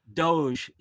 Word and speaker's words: DOGE: Doge.